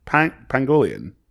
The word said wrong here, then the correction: pangolin: It's not Pangolian. – No. – It's not. – No. – I've been saying this wrong forever. – I think so, yeah. – Oh, yeah, no. It's definitely not Pangolian.